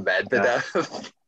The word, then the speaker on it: Medvedev: I don't know how to pronounce it. Medlov? Medlov? – Vedev or whatever? – Yeah. Danielle. Danielle. – Yeah. Was it good? – Danielle Medvedev. Yeah.